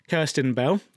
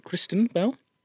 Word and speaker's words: Kirsten Bell: So one such anthropologist, Kirsten Bell, who I made sure to pronounce her name properly, as she's often called Kristen Bell, wanted to learn more about farts, but she found very little prior research, but she was determined to find out more.